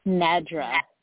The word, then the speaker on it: Nedra: Nedra.